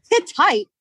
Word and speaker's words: Hittite: Hit tight? What does that even mean? – Hit. I'm going to tell you. It's a dictionary. – Can you spell it first, please? – Yeah. It's hit tight. H-I-T-T-I-T-E.